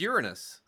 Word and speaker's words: Uranus: But that's not how it's pronounced, though. – What? – It's Uranus.